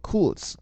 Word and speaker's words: Kurz: 这个 R 字母在德语当中似乎是有一个小舌音的震颤，但我发不出来，没关系啊，反正我看很多美国人在念这个名字的时候，也都念作叫做 Kurz 啊，差不多这样子。